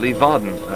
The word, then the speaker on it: Leeuwarden: That's B-A-K-K-E-R. And he lives in Leeuwarden. Leeuwarden? Vauden. Leeuwarden. I do believe that would be how it's pronounced.